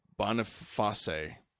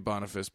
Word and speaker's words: Boniface: And as the Christians like to do, so in 609 AD, Pope Boniface. It could be Boniface, but that's not how the Latin pronunciation would be.